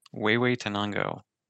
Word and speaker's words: Huehuetenango: Huehuetenango.